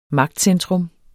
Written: magtcentrum

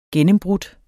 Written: gennembrudt

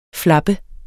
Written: flappe

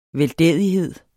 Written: veldædighed

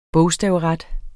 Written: bogstavret

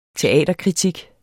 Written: teaterkritik

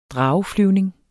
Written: drageflyvning

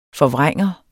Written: forvrænger

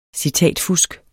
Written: citatfusk

